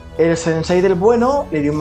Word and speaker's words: sensei: De que al malo malote, como ve que le está ganando, su sensei, ¿lo he dicho bien?